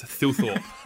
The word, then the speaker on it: Thilthorpe: Filthy, and it's because people couldn't pronounce Thilthorpe, and they changed it with Fs to Philthorpe, and then Filthy just stuck, I don't know.